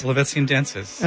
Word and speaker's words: Polovetsian: So that, tell me if I'm saying it right, the Polovetsian Dances? – Polovetsian Dances.